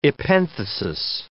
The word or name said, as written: epenthesis